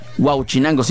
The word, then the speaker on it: Huauchinango: Huauchinango, sí, sí, sí lo dije bien. Porque una vez lo pronuncié mal, como si lo pronuncié como si fuera el pescado.